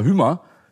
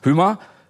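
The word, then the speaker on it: Hymer: Darüber wollen wir gleich sprechen und auch persönlich werden, denn beim Wohnmobil- und Wohnwagenbauer Hümer, spricht man das Hümer aus, Hümer, äh, von dem Jacqueline zur Lufthansa kam, hat sie ihren Job auf ganz besondere Weise ergattert.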